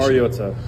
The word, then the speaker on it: Mariota: Mariota. I think it's been said over the years like Mariota, which is incorrect, and it's pronounced Mariota.